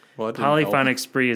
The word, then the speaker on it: Polyphonic Spree: Polyphonic Spree is, I believe, how it's pronounced.